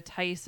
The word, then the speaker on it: Tice: Tice is spelled T-I-C-E, is play off of our last name.